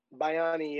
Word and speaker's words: Bayani: So it's like, you know, so could you talk about like, and tell me if I'm saying this wrong. So the series is called The Realist Bayani. – Bayani, yeah.